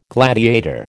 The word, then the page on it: gladiator: American English